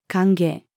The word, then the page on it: 歓迎: female